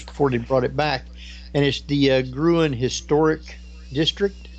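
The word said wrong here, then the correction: Gruene: It's Green. It's actually pronounced Green. It's G-R-U-E-N-E. And, of course, that's German, Grüne, which means green. So they spell it Grüne, but because we're Texans, we pronounce it Green.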